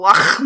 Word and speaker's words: Loch: She is our local Loch Monster. – Loch. – She's our Loch Monster. – You gotta pronounce the H. – Yeah, yeah. She's our local Loch Monster.